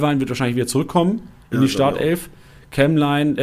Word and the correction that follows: Kemlein: äh, sorry, ähm, ich habe falsch ausgesprochen. Kämlein. Ähm, Kemlein, hat er schon mir beigebracht. Kämlein ähm, wird wahrscheinlich wieder rausrotieren.